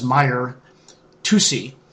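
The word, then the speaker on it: Mahyar Tousi: Here's a recent broadcast by host, I believe it's pronounced Meyer, Tusi, on his Tusi TV channel on YouTube about these latest developments of the war.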